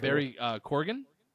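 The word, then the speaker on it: Barry Keoghan: Barry Corgan.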